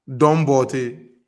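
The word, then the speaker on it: Don Bortey: Dombote. – I spell that, bro. – Dombote. – D-O-N. Don. This normal don. Then the bote is B.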